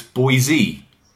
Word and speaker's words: Boise: I believe it's Boise.